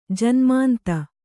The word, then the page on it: ಜನ್ಮಾಂಧೆ: janmāndhe